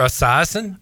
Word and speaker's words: Esiason: Boomer Esiason.